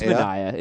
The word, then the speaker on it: Manaea: It's Manaea. It's Manaea.